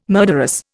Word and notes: murderous: Indian

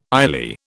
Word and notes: ILEA: British English

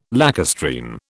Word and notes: lacustrine: British English

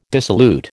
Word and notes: dissolute: American English

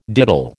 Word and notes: diddle: American English

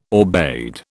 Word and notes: aubade: British English

